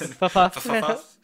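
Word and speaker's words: ffuf: fuff does, or fuff, however you say it. I hate trying to pronounce this. – Fuff.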